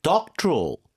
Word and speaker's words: doctoral: Doctoral. Doctoral. 그렇죠. 이름절에 강세가 가죠.